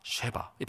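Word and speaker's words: 쉐바 (שבע): 이 일곱이라는 단어는 발음이 비슷한데 세바가 아니라 쉐바입니다. 세바, 쉐바. 이 발음이 비슷하죠.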